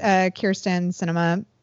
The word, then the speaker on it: Kyrsten Sinema: For the purposes of this episode, her name is Kyrsten Sinema.